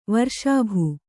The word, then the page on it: ವರ್ಷಾಭು: varṣābhu